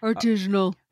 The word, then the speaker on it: artisanal: Is that artesian? Artisanal? – Oh, yes. Artisanal.